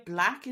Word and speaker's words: Blaque: Black is spelled B-L-A-Q-U-E.